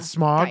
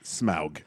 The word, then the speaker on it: Smaug: So it's with smog. – Yeah. – It's the nothing in smog. – First of all, it's pronounced Smaug.